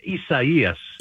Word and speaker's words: Isaias: Please tell us how to pronounce it. – Really, I mean it's Isaias. – Isaias. – Isaias.